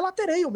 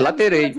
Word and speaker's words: latereio: Como é a pronúncia que se fala quem vai bater o lateral na área hoje? – É latereio mesmo, Zé. É latereio.